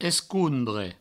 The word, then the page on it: escondre: provençal; escouondre